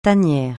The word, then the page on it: tanière: francais